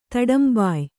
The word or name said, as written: ತಡಂಬೊಯ್